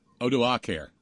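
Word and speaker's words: Odoacer: Now, how do you say it again? – Odoacare. – Odoacare, okay. – Most Latin words get an emphasis on the penultimate syllable.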